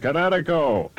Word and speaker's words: Kinetico: Everybody knows that Kinetico is spelled K-I-N-E-T-I-C-O. – Well, I thought the instructor said Connecticut. Oh, I mean, son, you should have remembered Kinetic as in kinetic energy with an O on the end. Kinetico. – Kinetico. Spell it K-I-N-E-T-I-C-O.